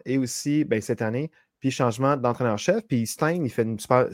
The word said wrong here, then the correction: Steichen: Stein, je ne sais pas si je le prononce bien, mais le coach des Colts fait une super... – Steichen. Oui. – OK.